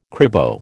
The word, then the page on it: cribo: American English